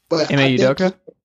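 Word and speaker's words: Ime Udoka: M.A. Udoka? – There you go.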